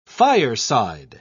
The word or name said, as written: fireside